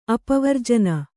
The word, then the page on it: ಅಪವರ್ಜನ: apavarjana